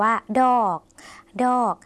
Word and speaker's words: ดอก: แปลว่าบอนะคะหลายในภาษาลาวค่ะภาษาลาวหลายหมายถึงมากค่ะคุณผู้ชมภาษาลาวไม่มีการใช้คำว่ามากนะคะจะใช้แต่คำว่าหลายค่ะส่วนคำลงท้ายคำว่าดอกคุณผู้ชมต้องลากเสียงยาวสักหน่อยนะคะเพื่อจะแสดงความสุภาพในภาษาลาว